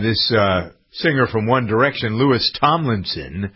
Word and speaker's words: Louis: It's just Louis, because it's a French pronunciation.